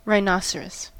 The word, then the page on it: rhinoceros: American English